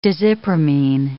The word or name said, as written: desipramine